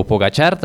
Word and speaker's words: Pogačar: O Pogačar también es la pronunciación correcta.